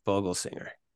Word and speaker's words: Vogelsinger: Is that how you, I don't know how to say his last name. – That is how I would say it. Brett Vogelsinger.